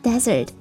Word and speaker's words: desert: 来拼写一下沙漠 D-E-S-E-R-T，读作 desert， desert，沙漠 desert。